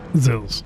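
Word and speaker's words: Zils: is it Zillis? – Zills. – Oh. Zills. Sorry, I want to make sure I pronounce it correctly. – As I'm sure you know, it is the Latvian word for blue.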